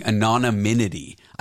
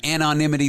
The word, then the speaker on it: anonymity: For my whole life, I was pronouncing it with an extra syllable for like no good reason. I was saying anonymity.